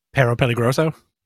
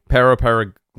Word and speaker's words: Perro Peligroso: How do you say this last name? – Perro Peligroso. – Thank you. Perro Peligroso.